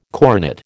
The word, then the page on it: coronet: American English